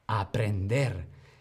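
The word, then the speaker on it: aprehender: Pero ojo, porque lleva una H intercalada. Aprender. Sí, se pronuncia igual que sin H, pero con H significa apresar, capturar a alguien o algo.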